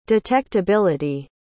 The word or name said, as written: detectability